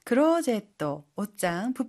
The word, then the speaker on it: クローゼット: クローゼット、옷장, 붙박이장인데요. 혹시 발음 팁 있을까요? – はい、チェアは의자를 뜻하는 영어 chair의 일본어 발음입니다. 그리고 클로젯은 중간에 장음, 소음에도 신경 써주세요.